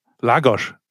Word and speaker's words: Lagos: In äh, äh, ja, Lagosch. Das hast du stark ausgesprochen.